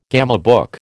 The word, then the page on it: gamebook: American English